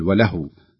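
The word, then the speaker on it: ولهو: هناك ملحظ بسيط في قوله وله، يعني بعض الناس عندما يقف على كلمة وله ولعب وله يشدد في الواو، وهي ليس عليها شدة، لا يوجد على الواو شدة، المفروض أن تنطق بهذا الشكل وله وله، تشدد عندما نصلها، لأن إذا وصلناها يكون تنوين، والتنوين يدغم فيما بعدها وهو حرف الواو، فيكون هناك شدة.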